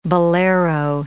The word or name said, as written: bolero